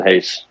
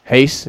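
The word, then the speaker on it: Reis: Henry Cejudo beat Wilson Reyes, or hey, well, how do you say that? – Wilson Hayes. – Hayes, yeah. Very, yeah. These guys, man. Oh, there's an R there. Why is the R there?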